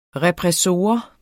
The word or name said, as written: repressor